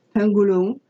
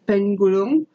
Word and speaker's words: penggulung: Pangolins come from a Malay word spelled P-E-N-G-G-U-L-U-N-G, pangolung, which is what the internet told me is that's how that's pronounced. Pangolung. – Pangolung.